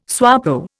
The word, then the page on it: SWAPO: Indian